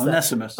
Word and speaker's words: Onesimus: help me pronounce Onesimus. – That. Onesimus.